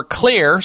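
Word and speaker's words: Xlear: You're looking for Clear, spelled with an X, X-L-E-A-R, Rescue Nasal Spray.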